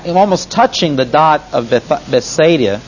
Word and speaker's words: Bethsaida: Bethsaida, I'm sorry. You keep mispronouncing it.